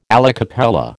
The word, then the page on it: alla cappella: American English